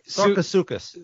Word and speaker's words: Sarcosuchus: Well, speaking of, you're going to have to help me with the pronunciation of this. The Sarcosaurus? – That looks about right. I can't even see it. – Right there. – Sarcosuchus. – Sarcosuchus? – Yes.